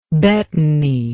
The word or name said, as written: betony